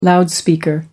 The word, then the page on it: loudspeaker: American English